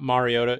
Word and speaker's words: Mariota: It's not Mariota. It's Mariota. – No, it's Mariota. We heard him say it himself, actually.